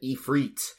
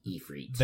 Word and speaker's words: Efreet: Natives of the elemental plane of fire, Azers are master crafters, expert miners, and sworn foes of the Ifrit, which is spelled E-F-R-E-E-T, Ifrit.